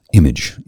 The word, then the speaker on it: Image: Image, pronounced Image uh, en français, but it was um, out of Toronto the uh, can't remember the name of the manufacturer right now.